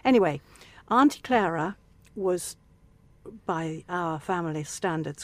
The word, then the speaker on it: Clara: Clara. Everybody says Clara now, but the English pronunciation, certainly in the 50s, 40s and 50s, was Clara.